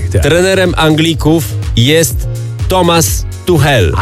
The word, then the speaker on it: Tuchel: A ty wtedy powiedziałeś, że on się wymawia Tuchel.